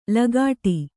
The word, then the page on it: ಲಗಾಟಿ: Kannada